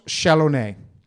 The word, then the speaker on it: Chalonnais: One with Pinot Noir, and there's an area called Côte Chalonnais. – How do you spell that? Côte? – C-O-T-E. – Yep. – Chalonnais is like C-H-A-L-O-N... N-A-I-S-E. I'm going to put this... – Like mayonnaise.